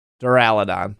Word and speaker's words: Duraludon: Duraludon.